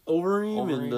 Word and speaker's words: Overeem: It's just Ulstream. How do you say it? – Overeem, and I can't think of the guy he's fighting, but he's from like Senegal.